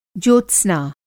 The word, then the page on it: ज्योत्स्त्रा: Hindi